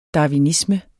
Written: darwinisme

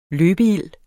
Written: løbeild

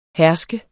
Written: herske

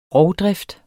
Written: rovdrift

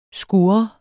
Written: skur

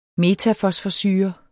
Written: metafosforsyre

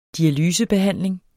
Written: dialysebehandling

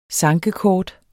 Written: sankekort

